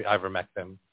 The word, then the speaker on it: ivermectin: and she sued them to give her husband ivermectin.